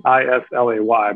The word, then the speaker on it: Islay: It's spelled Islay, Islay, but it's pronounced Islay.